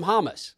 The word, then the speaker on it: James: I'm like, I, who is this James that is breaking out on the scene, and why do they keep calling him Hamas?